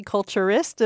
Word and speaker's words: culturist: I was afraid I was going to say that wrong. Coffee culturist. Am I saying that right? – You certainly are.